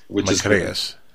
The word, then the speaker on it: Macreas: Well, you know, you're pronouncing it as it should be in Greek, Macreas. – Macreas.